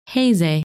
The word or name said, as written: Heze